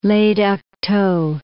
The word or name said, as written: Le Duc Tho